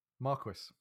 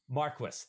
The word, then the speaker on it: Marquess: Marquess, is that it? – Marquess. – Marquess, thank you.